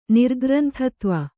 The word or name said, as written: ನಿರ್ಗ್ರಂಥತ್ವ